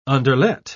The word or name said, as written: underlet